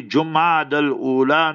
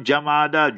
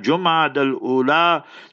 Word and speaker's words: Jumad al-Ula: Pronounce the month correctly, Jumad al-Ula, not Jamada, Jumad al-Ula.